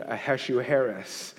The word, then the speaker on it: Ahasuerus: King Ahasuerus.